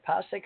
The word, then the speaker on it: Passaic: I don't know how you say P-A-S-S-A-I-C. – Yeah, that's right. – How do you say it? Passaic or Passaic? – Passaic. You had it right the first time.